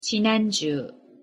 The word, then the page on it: 지난주: Korean